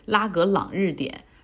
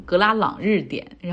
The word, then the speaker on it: 拉格朗日点: 我这个文稿里写的是拉格朗日点，然后我能读成格拉朗日点，然后以至于我自己读的时候也觉得，哎，这个地方好像跟西藏的某个地名很相近。